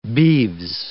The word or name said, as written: beeves